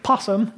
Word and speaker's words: opossum: We typically pronounce it possum instead of opossum, but opossums are, they're kind of like buzzards.